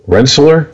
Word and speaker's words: Rensselaer: Rensselaer, R E N S S E L A E R, Rensselaer.